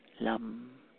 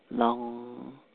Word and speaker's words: Lam: Have the thought, Lam. Actually, you can pronounce it Long. That M sound is a nasal, Lam.